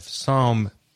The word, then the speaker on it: Psalm: It's actually from Psalm 115, Psalm, P-S-A-L-M, the P is silent.